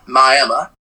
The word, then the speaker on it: Miami: Well, in Miami, Oklahoma, or as they like to say there, Miami, I kid you not, they call it Miami, it's spelled Miami, but they call it Miami, Oklahoma.